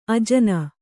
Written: ಅಜನಿ

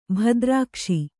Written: ಭದ್ರಾಕ್ಷ

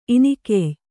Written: ಇನಿಕೆಯ್

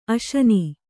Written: ಆಸೀನ

